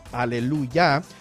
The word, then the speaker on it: aleluya: Este último elemento de aquí, estas últimas dos letras, es la abreviación, se pronuncia ya, por eso es aleluya.